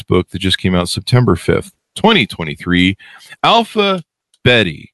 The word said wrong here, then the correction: Bette: Did I that pronounce correctly? Alphabetty, Jennifer? – That's a good question. I tend to call her Alpha Bette.